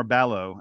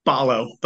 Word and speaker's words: Ballo: Let's get to the big men really quick, because Arizona is big, and it all starts with Umar Ballo. Am I pronouncing that right, by the way? – Ballo, but close enough.